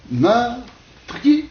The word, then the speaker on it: meurtrier: Donc, meurtrier, autrefois, deux syllabes. Pourquoi? Prenons cette terminaison « yé », il y a ce qu'on appelle une diphtongue. Par conséquent, une seule syllabe, n'est-ce pas?